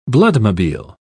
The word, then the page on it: bloodmobile: British English